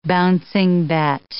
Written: bouncing bet

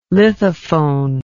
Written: lithophone

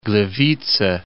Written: Gliwice

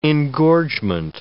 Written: engorgement

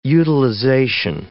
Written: utilization

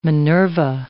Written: Minerva

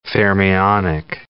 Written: fermionic

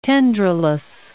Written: tendrilous

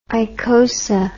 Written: icosa

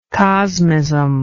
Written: cosmism

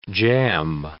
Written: jam

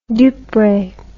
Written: dupre

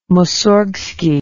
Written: moussorgsky

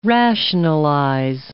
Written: rationalize

